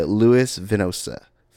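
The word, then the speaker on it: Louis Venosta: Curry III, and it was written by Louis Vinosta. That's how you pronounce that. There's a T in there.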